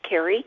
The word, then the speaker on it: Carrie: It's Carrie, spelled the right way, C-A-R-R-I-E.